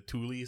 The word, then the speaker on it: Thule: Well, unfortunately, apparently it's actually pronounced like the Thule Society.